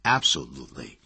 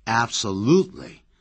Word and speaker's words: absolutely: absolutely 这个字不要念成按照字典的发音 absolutely。其实老外经常是念成 absolutely，重音在第三音节。